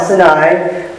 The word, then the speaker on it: Sinai: In Exodus 20, Moses came down from Mount Sinai. My kids say there's another pronunciation for that, but I think that is the one correct pronunciation.